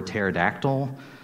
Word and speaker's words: pterodactyl: You see, the P is silent, like in pneumonia or pterodactyl.